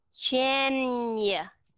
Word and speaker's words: January: January. Thì cái âm N cộng với âm long U sound nó sẽ ra âm NH của Việt Nam mình. January.